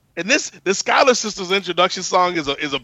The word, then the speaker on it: Schuyler: The Schuler sisters. – The Schuyler sisters. – Schuyler sisters. Yeah, I knew it was that.